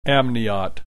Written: amniote

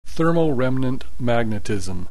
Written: thermoremnant magnetism

